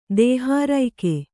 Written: ದೇಹಾರೈಕೆ

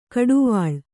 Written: ಘಟ್ಟಿವಳ

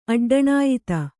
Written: ಅಡ್ಡಣಾಯ್ತ

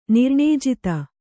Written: ನಿರ್ಣೇಜಿತ

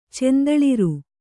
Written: ಚಂದಳಿರ್

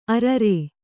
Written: ಅರರ

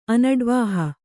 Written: ಅನಡ್ವಾಹಿ

